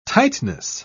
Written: tightness

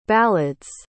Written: ballads

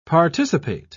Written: participate

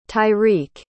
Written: tyriq

